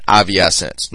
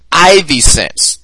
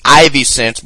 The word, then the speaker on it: iviSense: Their name is actually pronounced Ivy Sense. That second I is like a Y. It's Ivy Sense,